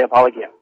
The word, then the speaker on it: apologia: And let me thank you for using the proper pronunciation. People know that say apologia.